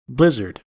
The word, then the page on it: blizzard: American English